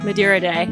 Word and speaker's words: Madeira Day: Madeira Day.